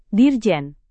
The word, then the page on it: dirjen: abbreviation